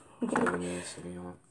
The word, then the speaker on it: Cabernet Sauvignon: And the wine of the weekend for me is the Carnivore Cabernet Sauvignon. Did I say it right? – Cabernet Sauvignon. – Yes.